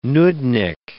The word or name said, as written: nudnik